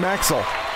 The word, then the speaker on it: Maxel: Dude, you know, it's King Maxel. – Maxel? What I call it? – That's how I spell it, M-A-X-E-L.